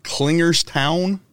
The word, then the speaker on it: Klingerstown: Man, you live in, help me pronounce the name of this. Is it Klingerstown? – Nailed it. Perfect.